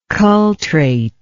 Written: cultrate

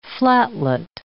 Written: flatlet

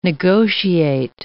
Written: negotiate

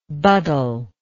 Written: buddler

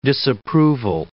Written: disapproval

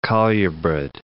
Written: colubrid

